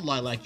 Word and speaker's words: LYLVC: So it's going to be LYLVC, band. That's on Instagram. LYLVC band. They're called Lilac.